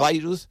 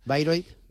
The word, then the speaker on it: Bayreuth: Bayreuth. – Bayreuth.